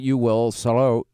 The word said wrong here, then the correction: Sderot: Sderot. Sderot. Sorry. Uh, and we saw the, they they put in glass, BBs, metal to, to Just maximize anything, yeah.